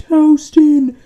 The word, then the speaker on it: Houston: If you pronounce it Houston Street, they're like, it's pronounced Houston.